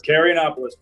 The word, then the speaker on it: Carayannopoulos: Carayannopoulos, pretty good.